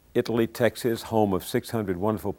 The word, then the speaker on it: Italy: There's a little town between Dallas and Waco called, I pronounced it Italy, I think the residents pronounce it Italy.